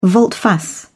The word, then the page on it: volte-face: British English